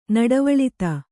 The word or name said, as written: ನಡವಳಿತ